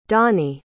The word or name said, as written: donny